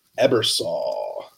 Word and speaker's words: Ebersole: Today, we have a special guest in the land space, an investor, Mr. Wayne Ebersole.